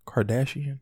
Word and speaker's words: Kardashian: Kardashian? – Yeah.